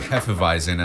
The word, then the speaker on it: Hefeweizen: Well, excuse me while I take another sip of this Golden Road Hefeweizen, and I hope I'm pronouncing that correctly. – You are.